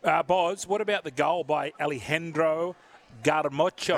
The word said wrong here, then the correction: Garnacho: Am I saying his name correctly? Garnacho. – Yeah, Garnacho.